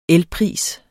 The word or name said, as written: elpris